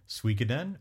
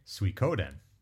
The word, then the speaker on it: Suikoden: We have two ways of pronouncing it, Suikoden or your way, Suikoden. – I do say Suikoden, yeah.